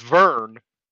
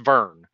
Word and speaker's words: Vearn: Now it's Vern, V-E-A-R-N. But it's pronounced Vern.